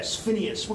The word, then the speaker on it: Phineas: This is the classic case, and you've heard about the case of Phidias Gage. The psychology students I know have heard of Phidias Gage. Right? If you've not heard about Phineas Gage, it's spelled P-H-I-N-E-A-U-S. Phineas.